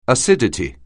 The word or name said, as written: acidity